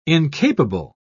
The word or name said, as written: incapable